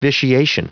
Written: vitiation

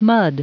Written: mud

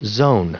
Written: zone

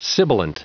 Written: sibilant

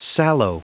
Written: sallow

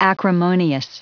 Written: acrimonious

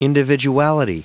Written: individuality

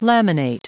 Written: laminate

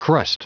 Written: crust

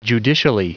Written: judicially

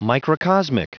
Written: microcosmic

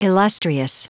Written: illustrious

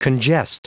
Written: congest

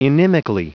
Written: inimically

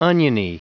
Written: oniony

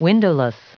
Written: windowless

